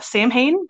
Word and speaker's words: Samhain: It's spelled Samhain, but it's pronounced Samhain, just for those of you that aren't familiar with that different pronunciation.